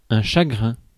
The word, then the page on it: grief: American English; [ɡɹiːf]